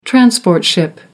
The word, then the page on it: transport ship: American English